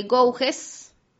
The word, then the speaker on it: Gouges: Olimpia de Gouges, obviamente pronunciado en francés, mujer campesina y analfabeta que emigró a París y se convirtió en escritora.